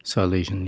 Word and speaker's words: Silesian: Am I saying that right? Silesian? – Silesian, yeah.